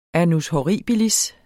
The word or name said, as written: annus horribilis